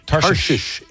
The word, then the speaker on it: Tarshish: Hopefully I'm pronouncing this right. I think it was at Tarshish. Am I saying that right? – Tarshish is correct.